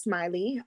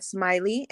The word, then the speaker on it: Smily: I am on Facebook as Chef Smily, and Smily is spelled with a uh, Y, Y, no E, S M I L Y.